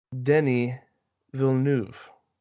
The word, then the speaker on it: Denis Villeneuve: None other than Denis Villeneuve. Now, I would like to point out to our dear listeners, I have pronounced that perfectly for anyone who is French.